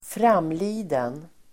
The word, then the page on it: framliden: [²fr'am:li:den]